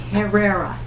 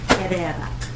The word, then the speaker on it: Herrera: Herrera. Herrera. H-E-R-R. – We were practicing rolling our R's, and I lost.